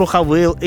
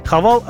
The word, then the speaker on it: Haval: Меня специально проинструктировали насчет того, как правильно произносить название машины. Иначе бы я на голубом глазу сказал так: Haval H6 Coupe. Ну, то есть точно так же, как и любой другой житель нашей страны.